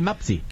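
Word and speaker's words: Mpasi: No va a sonar muy conocido porque la pronunciación es Lionel Mapsi.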